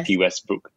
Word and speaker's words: Tati Westbrook: Velvet Vanity was actually featured on, I'm not sure if I'm pronouncing her name right, Tati Westbrook. – Yes. – Tati Westbrook.